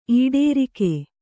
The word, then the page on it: ಈಟರಾಗ: īṭarāga